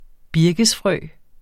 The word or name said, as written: birkesfrø